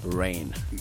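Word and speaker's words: Rennes: They drew the match against, is this Reigns? – Rain. – Yeah. – The S is silent.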